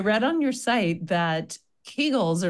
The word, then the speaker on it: Kegel: And because he's German, you would say Kegel.